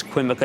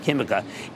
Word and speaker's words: Química: Now, I still can't recommend Sociedad Química, and you might think that's Quimica. It's Q-U-I-M-I-C-A, but they pronounce it Química in Chile.